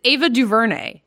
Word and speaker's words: DuVernay: It's Ava DuVernay. It's just Ava.